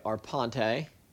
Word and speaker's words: Arpante: So I'm sitting here with Greg Arpante. – Nailed it, man.